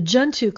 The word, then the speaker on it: Junto: Is it Junto Club or Junto Club? – I think the Junto Club. – The Junto Club.